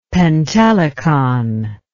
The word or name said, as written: pentelikon